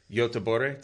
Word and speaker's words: Göteborg: How does the weather in Southern California compare to Göteborg? Did I pronounce that right? – Yeah.